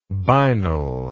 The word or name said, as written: binal